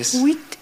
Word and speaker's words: huit: Huit. ¿Así? Sí. ¿Lo dije bien? – Sí. – En francés. – Huit.